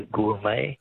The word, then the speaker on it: gourmet: Words that end with a silent T. Beret is B-E-R-E-T. Which word for a connoisseur comes from an old French word meaning wine taster? – Be a gourmet. – Gourmet, yes. Correct. G-O-U-R-M-E-T.